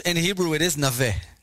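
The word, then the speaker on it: Naveh (נווה): It's Tzion Leshem of Naveh. Is that the right pronunciation? You keep saying Naveh. – Whatever. I'm Israeli, so Naveh.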